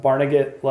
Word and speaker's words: Barnegat: And then we hit the road to drive across New Jersey to Barnegat Light State yeah. – Park. Is that the right way to say it? Okay. – Mm-hmm.